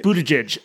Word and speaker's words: Buttigieg: Someone remind me how to pronounce it. – Mayor Pete. – Just Mayor Pete. – Pete Buttigieg. Buttigieg.